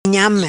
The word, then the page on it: Nhame: patua